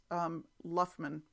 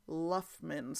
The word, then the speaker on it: Loughman: Maureen corrected my pronunciation of who I referred to as Gloria Laumann. It's Luffman It's spelled L-O-U-G-H-M-A-N, and Maureen tells me that it's Gloria Luffman.